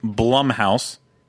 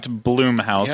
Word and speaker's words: Blumhouse: No, but then we're waiting on the, which I will now pronounce it correctly, because apparently I didn't last week, Blumhouse, not Bloomhouse, yeah, as I was saying before. Blumhouse, we're still waiting on that.